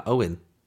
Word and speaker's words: Eoin: It just Ian? E-O-I-N. How do you say that? Owen.